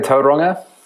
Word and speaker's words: Tauranga: Tauranga.